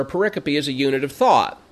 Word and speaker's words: pericope: So this pericope, and the word pericope here, it looks like periscope, only it's missing the letter S, and it's not pericope, it's pronounced pericope.